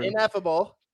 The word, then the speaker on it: Ineffable: And I think you added an extra in there. – I know, Hoppy was about to roast me again. Ineffable Brew. – Ineffable. I'll spell it for everyone. Ineffable. There's no D.